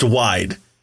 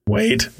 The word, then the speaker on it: Waid: How is it not pronounced wide? – Isn't it W-A-I-D? – It is. What does H-A-I spell? – Hi. – What does S-A-I spell? – Sigh. What does W-A-I-D spell? – Wade.